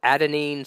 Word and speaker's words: adenine: Is it adenine, cytosine, thymine, and guanine? – Right.